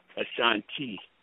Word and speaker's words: Ashanti: A-S-H-A-N-T-I. The I is pronounced with E. Asante. – Okay. – Wait. Hello? – A-S... Can you his name again, please? A-S-H.